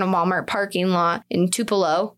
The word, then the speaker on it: Tupelo: So motorists discovered Nancy Carol Stevens' body on a roadside in, how do you say it, Tupelo? – Tupelo.